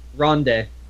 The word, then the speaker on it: Ronde: My personal favorite, like, really ugly Saturn game is probably Ronde? Like, it's spelled R-O-N-D-E. I don't know. – Ronde. – Yeah, Ronde.